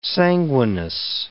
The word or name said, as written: sanguineness